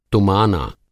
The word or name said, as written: तुमाना